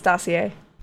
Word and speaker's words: dossier: I think it's dossier. – Dossier? – Yeah, I think it's dossier. – It's spelled D-O-S-S-I-E-R, and you know I can't pronounce things. So it's like it's Garnier.